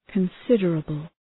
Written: considerable

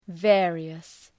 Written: various

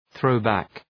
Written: throw back

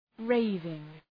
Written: raving

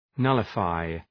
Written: nullify

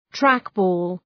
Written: trackball

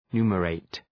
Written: numerate

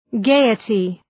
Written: gaiety

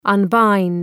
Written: unbind

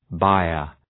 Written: byre

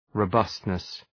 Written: robustness

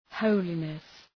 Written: holiness

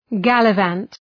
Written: gallivant

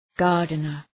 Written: gardener

